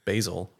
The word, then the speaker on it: basil: Did you say basil? – Yeah, because it's more fun. – Okay. – I know it's pronounced basil.